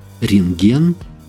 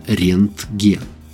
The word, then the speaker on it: Рентген: Правда, сложную немецкую фамилию часто произносят и даже пишут неправильно, редуцируя звук «т» в середине – «рентген» вместо «рентген».